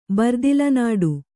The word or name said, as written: ಬರ್ದಿಲನಾಡು